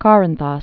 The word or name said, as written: Korinthos